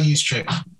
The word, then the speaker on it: Juszczyk: They should try to turn him into a juice chick. How you pronounce it? – Kyle Juszczyk.